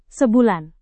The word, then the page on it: sebulan: indonesian